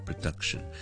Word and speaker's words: production: 正如我哋讲 production 可以制作呢个字咧，我哋就唔会读成 production，because duck 先系重音，production 前面嗰个咧我只会轻读成咧 production。 production，但系都系嗰句 check 字典会稳阵啲。